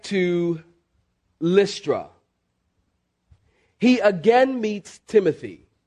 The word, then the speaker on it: Timotheus: One of those certain disciples would have been Timotheus. That's his Greek pronunciation. We pronounce it Timothy.